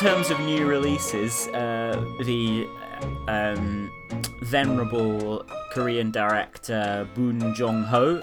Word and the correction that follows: Bong Joon-ho: has got a new film out called Parasite. Sorry, I pronounced his name wrong. Bong Joon-ho.